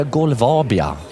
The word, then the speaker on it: Golvabia: Och du representerar Golvabia. Ja. Rätt uttal? Ja, Hemma, rätt.